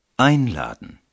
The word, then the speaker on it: einladen: উপসর্গ আইন এটার দিকে মনোযোগ দিন উচ্চারণের সময় উপসর্গের ওপর জোর দেওয়া হচ্ছে কারণ এটি একটি বিশেষ অর্থ প্রকাশ করছে einladen ich möchte dich einladen